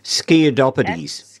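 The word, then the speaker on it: Sciadopitys: Sciadopides.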